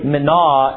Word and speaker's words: Mene: And so I've said Mene, I've said Mena. Really, Mena is probably the best way of describing it, but the words were written, so it didn't have to be pronounced anyway.